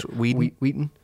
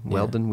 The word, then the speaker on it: Whedon: Josh Whedon, how you say his name? Whedon. – Josh Whedon, right? – Whedon. – Whedon. – Whedon. – Weldon. Whedon. Yeah.